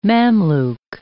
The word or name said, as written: Mamluk